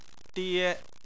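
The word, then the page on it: die: tone 1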